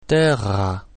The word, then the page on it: terra: German